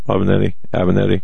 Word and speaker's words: Avenatti: And you said that was Stormy Daniels' lawyer, Avenatti. Isn't that how you pronounce his name? Avenatti. – Yeah, and Avenatti is making noises.